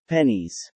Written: Pennies

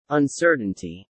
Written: uncertainty

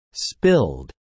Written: spilled

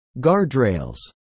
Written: guardrails

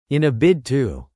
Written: In a bid to

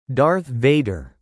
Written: Darth Vader

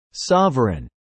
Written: sovereign

